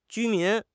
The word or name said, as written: 居民